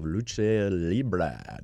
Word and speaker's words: lucha libre: Next, we'll journey into Dallas's underground world of lucha libre.